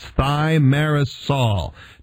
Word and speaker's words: thimerosal: So we have folks calling in wondering, how do you spell it and how do you pronounce it? That's thimerosal. Thimerosal.